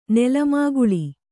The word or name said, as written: ನೆಲಮಾಗಳಿ